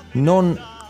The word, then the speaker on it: non: So non, you have to make sure to pronounce the N at the end of that word because it's a little bit different from the word no.